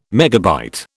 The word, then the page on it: megabyte: British English